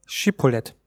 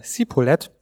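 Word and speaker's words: Schibbolet: Wenn er dann antwortet: Nein, so sprachen sie zu ihm: Sag doch Schipolet. Sagt er dann Sipolet, weil er es nicht recht aussprechen konnte, so ergriffen sie ihn und töteten ihn an der Furt des Jordan, so dass zur Zeit von Ephraim 42.000 fielen.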